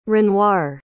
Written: renoir

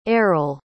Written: eryl

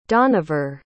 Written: donaver

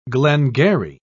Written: glengarry

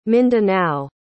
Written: mindanao

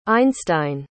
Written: einstein

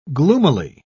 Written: gloomily

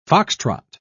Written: foxtrot

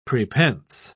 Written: prepense

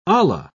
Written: olla

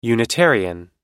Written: unitarian

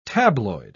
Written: tabloid

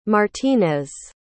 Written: martinez

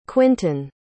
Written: quinten

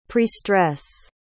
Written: prestress

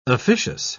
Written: officious

